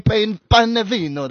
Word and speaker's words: Panevino: Giovanni Panevino. That's how you say it.